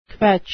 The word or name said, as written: kvetch